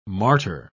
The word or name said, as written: martyr